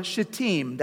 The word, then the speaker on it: Shittim: Joshua and all the Israelites set out from Shittim, that's how it's actually pronounced in the Hebrew, Shittim, and went to the Jordan River.